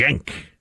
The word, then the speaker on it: Cenk: Cenk is how you pronounce Cenk. His name. – All right. – Cenk.